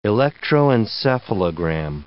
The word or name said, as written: electroencephalogram